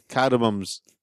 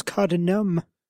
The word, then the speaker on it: cardamom: Cardamoms. Yeah. Cardamoms. – Like tiny mums wearing cardigans. – Yes. How do you say it? Is it cardamom or cardamom? – I thought it was cardinum. – No, it's cardamom. M-O-M.